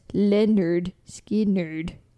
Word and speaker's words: Lynyrd Skynyrd: He signed them to Sounds of the South and produced their first record, which is called, pronounced Lynyrd Skynyrd, which was released on August 13th, 1973.